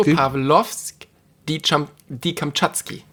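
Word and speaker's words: Petropawlowsk-Kamtschatski: Petropavlovsk, die Kamtschatski. – Ja. Das hast du gut gelernt. – Ich habe es richtig ausgesprochen. – Ja, war gut. Ja, ne? War gut. War schön.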